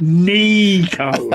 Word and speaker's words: Nico: You pronounce it Nico, okay. Like with E's.